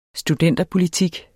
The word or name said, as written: studenterpolitik